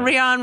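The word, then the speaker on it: Rheon: Rion rhymes with Theon. That we can maybe guess.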